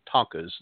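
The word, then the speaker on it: thangkas: They're called tonkas, not T-O-N-K-A-S like the trucks. People always make that joke. They go, oh, I got a tonka truck. It's spelled T-H-A-N-K-H-A.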